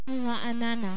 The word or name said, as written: Raanana